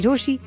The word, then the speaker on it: Rochi: मेरा नाम है मिर्ची रोशी, नाम की स्पेलिंग है ROCHI.